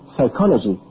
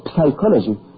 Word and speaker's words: psychology: When you say, for example, psychology, you don't say psychology. The P is silent.